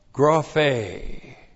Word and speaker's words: graphe: All graphe. All graphe. G-R-A-P-H. Long E.